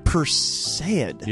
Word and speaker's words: Perseid: It's called the Perseid meteor shower. – Perseid? – Yeah, I believe that's how it's pronounced.